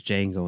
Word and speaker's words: Django: And then Jamie Foxx, you know, says Django and then spells it DJANGO and says the D is silent.